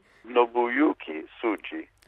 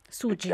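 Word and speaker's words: Nobuyuki Tsujii: Nobuyuki Tsuji, è un po' difficile pronunciare il nome, spero di averlo detto bene. – Nobuyuki Tsuji. – Tsuji, ecco, sì.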